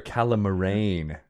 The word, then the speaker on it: Calamarain: These are Calamarain. Very different. It's accent on the end.